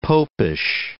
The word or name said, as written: popish